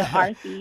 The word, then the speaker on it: Arti: The Arthi, yep.